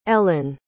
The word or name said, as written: ellen